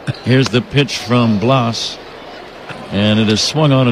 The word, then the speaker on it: Blas: He spells it B-L-A-S. Take a few guesses, Ron. How would you pronounce B-L-A-S? – George.